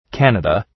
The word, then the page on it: Canada: ‘kænədə